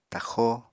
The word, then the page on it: taxó: apatani